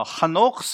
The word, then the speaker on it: Hanoch: Now, Hanoch's faith, by the way, that's the way you pronounce Enoch. I don't know where we get Enoch from.